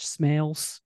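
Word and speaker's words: Smails: Judge Smales? – That's it.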